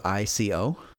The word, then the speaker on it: ICO: I always pronounce it Ico. It's uh, from one all reports that I've heard, it's pronounced Eco because it's Japanese.